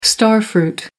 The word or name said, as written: starfruit